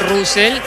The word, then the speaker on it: Russell: No, pronunciación la real es Russell. – Russell. – Sí. Bien, muchos le dicen Russell. Está perfecto, pero la pronunciación correcta es Russell.